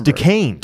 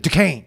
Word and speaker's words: Duquesne: to think how to pronounce Desquaney in Pittsburgh, and I couldn't remember. – It's Duquesne. – Duquesne.